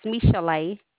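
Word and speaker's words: Michel'le: But you pronounce it Michelle, huh? – No, sir, it's Michelle.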